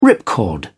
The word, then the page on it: ripcord: British English